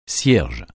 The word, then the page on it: cierge: français